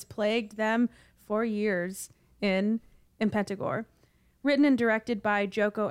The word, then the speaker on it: Impetigore: Impetigore? Yeah. I've heard it said Impetigore, like almost they say the T like a D, but who knows?